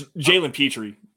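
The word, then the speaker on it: Jalen Pitre: It's Jalen Petrie. Jalen Petrie. Okay.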